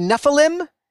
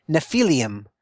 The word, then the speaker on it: Nephilim: I think it's legitimate to say Nephilim. I actually, the scholarship I've read makes the argument that the actual Hebrew pronunciation would be more Nephilim, but that's too hard to say. So just Nephilim is fine.